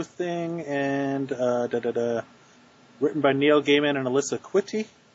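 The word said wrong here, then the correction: Kwitney: I think it's Quitney. – Quitney.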